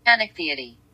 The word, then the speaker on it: Chanichthyidae: The scientific name of an ice fish is Chanichithiidae. Chanichithiidae. Ooh, that's hard. – Chanichthyidae. – Chanichthyidae? – Chanichthyidae. – Chanichthyidae. Okay, how you say it is Chanichthyidae.